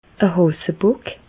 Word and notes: e Hàsebock: Bas-Rhin; Reichshoffen

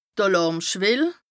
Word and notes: d' Àlàrmschwell: Bas Rhin

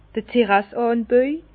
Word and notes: d'r Terrasseànbaü: Bas-Rhin; Herrlisheim

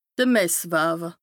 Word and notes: de Messwaawe: Bas-Rhin

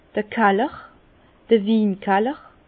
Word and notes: d'r Kaller: Bas-Rhin; Herrlisheim

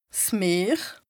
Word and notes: 's Meer: Bas-Rhin; Strasbourg

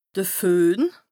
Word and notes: de Föhn: Bas-Rhin; Strasbourg

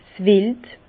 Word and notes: 's Wild: Bas Rhin; Herrlisheim

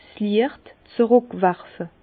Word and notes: 's Liecht z'rùckwerfe: Bas Rhin; Herrlisheim

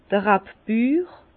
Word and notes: d'r Rabbür: Bas-Rhin; Herrlisheim

